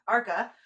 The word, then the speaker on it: Arca: she as well performed with Arca. That's how you say it, right? Yeah. Arca.